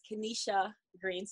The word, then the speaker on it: Kenesha Green: My name is Kenesha Green. So the I is silent.